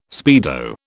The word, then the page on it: speedo: British English